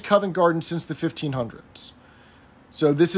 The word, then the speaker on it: Covent Garden: Anyway, so their prize was a box at the ballet at Covent Garden for six people. But Harmon mispronounces it as Convent Garden.